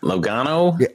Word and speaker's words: Logano: Logano.